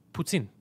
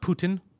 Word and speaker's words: poutine: It's poutine. It's P-O-U-T-I-N-E. Poutine. Not Putin. Not Putang. Poutine.